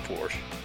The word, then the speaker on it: Porsche: It's pronounced Porsche.